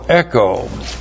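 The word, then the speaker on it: Echo (ἔχω): E-C-H long O. Echo.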